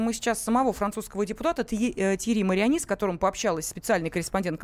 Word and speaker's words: Тьерри Мариани: И как и в прошлом году, его возглавлял депутат Нижней палаты французского парламента, законодательного собрания от партии «Республиканцы», Тьерри Мариани. Но в этот раз... – Простите, вас поправлю немножечко. Тьерри Мариани, потому что это французы. У французов идет ударение на последний слог.